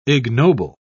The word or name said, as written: ignoble